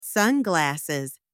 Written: sunglasses

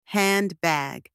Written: handbag

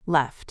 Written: left

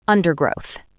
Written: undergrowth

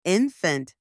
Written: infant

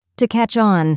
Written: to catch on

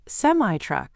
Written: semi-truck

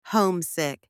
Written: homesick

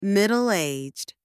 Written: middle-aged

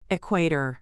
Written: Equator

